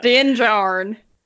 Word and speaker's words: Din Djarin: So we do this flashback to, so it's the full flashback of Din Djarin. Is that how you pronounce his name? I don't know. – Din Djarin.